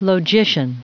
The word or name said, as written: logician